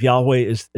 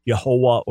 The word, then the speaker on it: Yahweh: I believe Yahweh is our best understanding. I reject the idea that it was originally Yehovah or Yehovah, but put that aside.